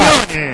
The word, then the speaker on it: Marchione: Marchione. Perfecto castellano, Marchione, perfecto italiano, Marchione.